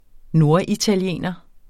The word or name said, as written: norditaliener